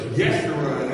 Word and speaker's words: Jeshurun: It says here, but Jeshurun, that's the pronunciation of that.